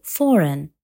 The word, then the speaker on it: foreign: لا يتم نطق الحرف G في المجموعة GN في نهاية الكلمة مثل align وsign أو foreign